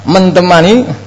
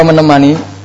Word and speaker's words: menemani: Mentemani, apa menemani? Menemani.